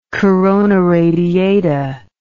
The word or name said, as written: corona radiata